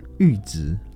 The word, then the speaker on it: 阈值: 例如说，我本来不知道阈值的那个阈怎么念呢？ – 阈值就是到了某个阈值。